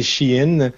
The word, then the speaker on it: Shein: En fait, bon, la prononciation anglophone, c'est Shein.